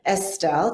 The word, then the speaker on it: Estill: Today we have Jim Estill.